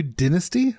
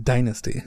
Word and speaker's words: dynasty: That's how it's pronounced. – No, it's pronounced dynasty. – No, it isn't. It's pronounced dynasty. – Oh, it's fucking dynasty, isn't it? Oh, shit. Real fucking Anglophile over here.